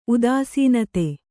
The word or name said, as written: ಉದಾಸೀನತೆ